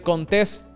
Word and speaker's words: Contés: Mi nombre es Miguel Contés con acento en la E.